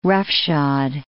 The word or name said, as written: roughshod